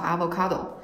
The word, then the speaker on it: avocado: 居住在一个全球移动是比较可能的时代，所以说，就像我第一次吃到螺蛳粉，第一次吃到牛油果，牛油果的，包括它的英文那个单词，它其实是西班牙语，叫 avocado，就是它这个，就是我当时学这个单词的时候，我说这个词儿怎么这么不英语啊？